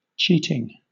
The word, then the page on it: cheating: British English